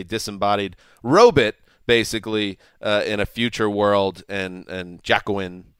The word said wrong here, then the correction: Joaquin: Is that how you pronounce it? – Joaquin Phoenix. – Joaquin.